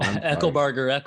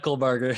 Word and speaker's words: Eckelbarger: Eckelbarger, Eckelbarger.